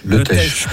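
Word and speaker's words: Le Teich: Le Tech, pardon. – J'avais pas la bonne prononciation.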